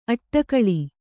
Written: ಅಡಿಗಲ್

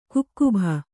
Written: ಕಾಕಬ್ಬು

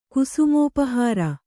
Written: ಕುಸುಮೋಪಹಾರ

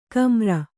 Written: ಕಮ್ಮಾರ

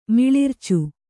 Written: ಮಿಳಿರ್ಚು

